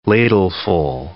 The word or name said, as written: ladleful